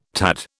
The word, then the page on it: tad: British English